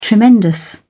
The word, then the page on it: tremendous: British English